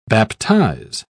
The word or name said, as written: baptize